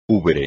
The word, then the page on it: ubre: espagnol